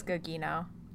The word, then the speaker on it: Gugino: I think it's Gugino.